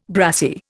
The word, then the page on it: brassie: Indian